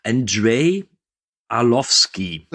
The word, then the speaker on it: Andrei Arlovsky: Und als Gegenspieler haben wir ja den, jetzt muss ich aufpassen, dass ich es nicht falsch ausspreche, Andrei Arlovsky.